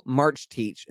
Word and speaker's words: Marcetic: If you want to look it up, it's spelled like Marcetic. Bronco Marchteach.